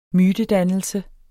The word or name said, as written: mytedannelse